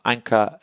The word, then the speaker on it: Anca: And if you're looking for this episode, just put Anka, A-N-C-A, into any of the search engines with Sodshow, and it should come up for you. In the meantime, Anka Elena Panay.